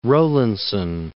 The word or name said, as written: Rowlandson